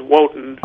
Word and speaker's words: Woten: So you're related to the Wootens down here? – No. – Oh. – My name is Wotan. – Oh, Wotan. – I said it wrong. I'm sorry. Okay. W-O-T-E-N is how you spell it, right? – Yes, exactly.